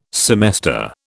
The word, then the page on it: semester: British English